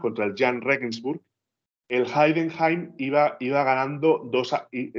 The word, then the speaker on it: Heidenheim: El Heidenheim. Heidenheim, sí, Y, y, y, cómo es que el Hamburgo ha celebrado un ascenso que no ha logrado, por Dios.